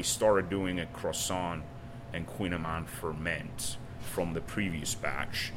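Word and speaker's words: kouign-amann: Queen Amman, it's pronounced queen as king and queen, and then Amman, A-M-A-double-N.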